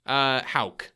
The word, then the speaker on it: Hauck: How would you pronounce Hauck? – Hauck. – Hauck. – There was a middle school in my hometown called Hauck. So I would know.